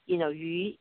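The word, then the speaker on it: yu (余): Because the fish, it's the same pronunciation, yu, yu in Chinese means leftover, like that, or savings.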